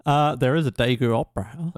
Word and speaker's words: Daegu: Does Daegu have... – Daegu. – How do you say it? – Daegu. – Daegu, sorry. Does Daegu have like landmarks?